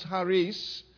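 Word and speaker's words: charis (χάρις): The Greek word for grace is charis. Literally, it's pronounced charis, and we get the English word charisma or charisma from it.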